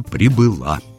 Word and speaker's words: прибыла: Ну, в женском роде в прошедшем времени ударение перескакивает на окончание, как довольно часто бывает. И получается «прибыла».